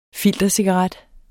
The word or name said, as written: filtercigaret